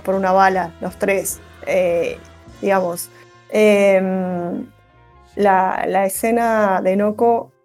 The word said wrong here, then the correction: NoHo: No, Noho. – Noho. – Con H. – Noho. – Es como el Soho, pero Noho.